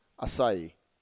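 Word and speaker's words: açaí: How on earth is this thing pronounced? Well, I've done a little bit of research. I love this stuff, all of that kind of thing. And I can tell you, it's pronounced acai.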